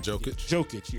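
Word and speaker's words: Jokić: Jokic.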